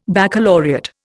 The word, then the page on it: baccalaureate: Indian